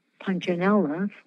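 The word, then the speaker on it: Punchinello: In 27, four syllables, da da da da, and the long syllable is the third one. Punchinella, four syllables, and the long syllable is the third one.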